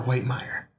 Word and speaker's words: Whitmire: Is it Whitmire or Whitmire? – Whitmire. – I thought so.